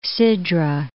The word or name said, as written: Sidra, Gulf of